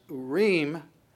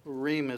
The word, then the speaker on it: Urim: so the word that he uses, Urim, would be the way that you pronounce it, Ur is the singular, Urim is the plural.